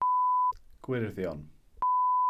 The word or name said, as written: gwyrddion